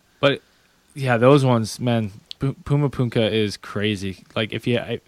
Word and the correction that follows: Puma Punku: Puma Punka? I always think I say it wrong. – Puma Punku, P-U-M-A-P-U-N-K-U. – Puma Punku. – Puma Punku. – Puma Punku. Puma Punku. – Puma Punku. – Puma Punku. Okay, I got it.